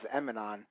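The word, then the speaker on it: Emanon: The name of the song, I always pronounce it as Eminon, but it's like a little thing where if you spell it backwards, it's just called No Name.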